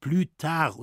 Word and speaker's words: plus tard: Das wird ausgesprochen plus tard und heißt später.